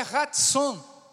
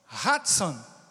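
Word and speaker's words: ratson (רצון): Então, favor no hebraico é ratson, ratson, que significa benevolência.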